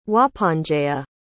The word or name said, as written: wahponjea